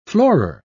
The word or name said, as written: floorer